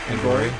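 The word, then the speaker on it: Angourie: Anna Gorey, how do I pronounce her name? – Anna Gorey. Yeah.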